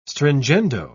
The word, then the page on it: stringendo: British English